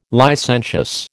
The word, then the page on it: licentious: American English